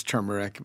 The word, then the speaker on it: turmeric: I mean, I've said turmeric. – Turmeric. – Who knows why I said turmeric when it's turmeric, you know, but I've been chastised enough.